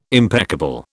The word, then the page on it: impeccable: British English